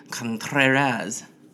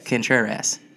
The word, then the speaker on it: Contreras: Mr. Anthony Contreras. – Well, I mean, I'm American, you can pronounce that Contreras, but yes. – Contreras. – Yes, that. Okay. – I emphasize the ass.